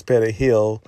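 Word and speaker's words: perejil: which is parsley's petahill. Petahill, that's what they call it in Spanish.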